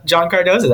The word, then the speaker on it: John Cardoza: Yes, that is John Cardoza.